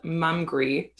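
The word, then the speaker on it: Mumgry: And it's a brand called Mumgree, spelled like hungry, but it's mum at the beginning.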